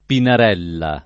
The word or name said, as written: Pinarella